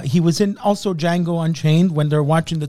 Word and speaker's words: Django: And the guy at the bar with Jamie Foxx, he says, it's pronounced with a J. And he goes, I know, because that's the original Django.